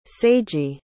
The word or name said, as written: seiji